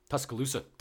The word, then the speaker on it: Tuscaloosa: Tuscaloosa? – Tuscaloosa.